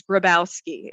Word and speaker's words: Grybowski: An example of that would be, so my last name is Grybowski.